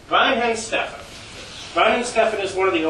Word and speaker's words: Weihenstephan: Weihenstephan. The thing about German words is you got to pronounce every letter. Yeah.